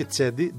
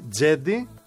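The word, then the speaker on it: Cedi: και είναι Τζέντι, όχι Τσέντι, Τζέντι Όσμαν. – Τζέντι. – Ο Τούρκος γεννημένος στην Οχρίδα. Μετά φυσικά, σαν τον Τζόκοβιτς. – Γιατί Μεσί. – Ναι, έτσι. – Είδα κάτω. – Μεσί είναι, αλλά προφέρεται Τζέντι.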